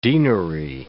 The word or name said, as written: deanery